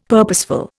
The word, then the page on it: purposeful: Indian